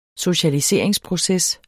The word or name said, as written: socialiseringsproces